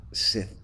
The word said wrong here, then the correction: scythe: Huh? Scythe. – Oh, Lord. I can't believe I just botched that.